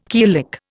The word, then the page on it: killick: Indian